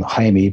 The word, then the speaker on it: Jaime: Now, in Espanol, they would pronounce his name Jaime,